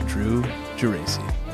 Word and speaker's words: Geraci: In this episode, we're sitting down with the master of time lapses, Drew Geraci. Did I say it right? – You said it right.